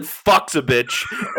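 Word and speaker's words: Fucsovics: I have Marcos Gurán plus 105 against Mark's boy Martin Fucsovich. Fucsovich, whatever. I'm trying. And then I have Sebastian Baez. – We are not trying. – Family show, right? It's a family show. You pronounce it Fucsovich.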